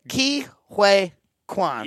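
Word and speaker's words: Ke Huy Quan: Ki Hue Kwan.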